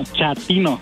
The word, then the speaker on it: chatino: Chapino. – ¿Chapino? – No, chatino. – Es como chato, ¿verdad? Chatino. – Sí, asunero como chato, chatino.